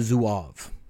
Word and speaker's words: Zouave: It's called the Zouave, and I looked up how to pronounce that before I got on, so it is appropriate. It's spelled Z-O-U-A-V-E.